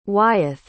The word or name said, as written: wyeth